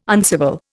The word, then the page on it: uncivil: Indian